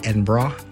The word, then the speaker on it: Edinburgh: I've heard people say Edinburgh, Edinburgh, but it's actually Edinburgh, like B-R-A at the end, Edinburgh.